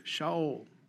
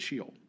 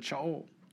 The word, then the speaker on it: Sheol: And then in our Old Testament, right, we read of Shaol, or as many people pronounce it, Sheol, but Shaol in the Hebrew.